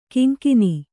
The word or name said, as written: ಕೊಂಕಾನ್